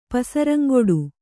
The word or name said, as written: ಪಸರಂಗೊಡು